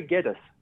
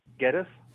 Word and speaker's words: Geddes: We say Geddes. – Geddes. Yeah. Geddes. How do you pronounce it? – I would say like lettuce. Geddes.